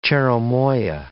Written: cherimoya

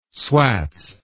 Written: swats

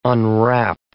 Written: unwrap

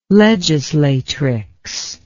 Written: legislatrix

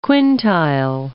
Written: quintile

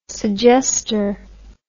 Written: suggestor

